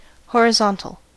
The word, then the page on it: horizontal: American English